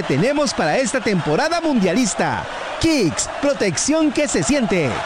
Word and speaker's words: Kixx: ¿pero cómo se escribe Kicks? Se escribe K-I-X-X. Kicks, protección que se siente.